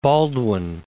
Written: Baldwin I

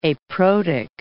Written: aprotic

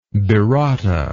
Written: birota

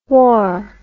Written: whaur

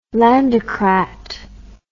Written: landocrat

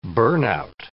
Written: burnout

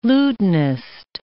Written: lutanist

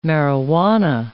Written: marihuana

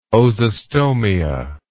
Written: ozostomia

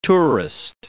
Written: tourist

